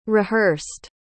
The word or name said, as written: rehearsed